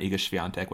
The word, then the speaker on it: Iga Swiatek: Yeah, Iga Swiatek. That's how you say it. Not that hard.